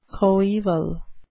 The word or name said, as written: coeval